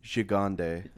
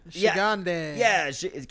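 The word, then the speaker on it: Gigandet: Gigande. – Gigande.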